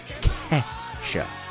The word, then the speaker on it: Kesha: I pronounced the performing artists as Keisha, and in fact, it's Kesha. Kesha.